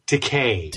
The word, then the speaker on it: Takei: Takei. Because it rhymes with gay.